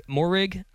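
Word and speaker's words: Moehrig: And instead, they have I have them taking Trayvon Morig, I think is how you say his last name.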